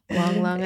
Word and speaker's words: Wang Lung: And even the little fool, I think what that did in the story was make Wang Chung. Wang Lung, I think. – Sorry. – I don't know how to pronounce it. – Thank you, Wang Lung.